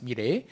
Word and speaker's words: Milet: donc ça s'écrit M-I-L-E-T, mais ça se prononce Miley.